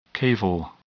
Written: caval

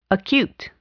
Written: acute

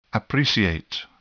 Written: appreciate

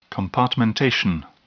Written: compartmentation

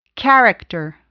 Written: character

